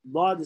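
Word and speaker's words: Dad (ض): Dad is coming from the side of the tongue. Each letter, if you want to pronounce correctly, give sukun. Ab, ab, Dad, Dad, Dad. That's the way.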